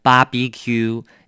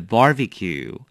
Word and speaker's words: barbecue: 小心它的重音哦。我常听大家念成 barbecue, 有没有？其实是 barbecue。